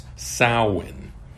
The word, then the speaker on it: Samhain: Secular theory gives us an indication that many of the Halloween traditions originated from Celtic harvest festivals, especially the Gaelic festival of what looks like Samhain, but is pronounced Samhain, which has pagan roots and was Christianized as Halloween or All Hallows Eve by the Christian church.